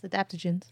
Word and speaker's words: adaptogens: adaptogens. – Yes, adaptogens.